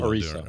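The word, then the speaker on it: Orisa: Still Arisa.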